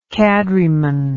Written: cadreman

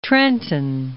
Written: Trenton

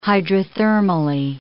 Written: hydrothermally

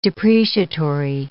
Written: depreciatory

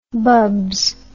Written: bubs

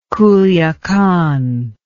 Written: culiacan

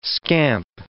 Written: scamp

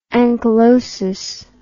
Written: anchylosis

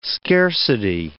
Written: scarcity

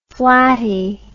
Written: flatty

